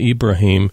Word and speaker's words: Ibrahim: Today we're talking with its author, Raymond Ibrahim.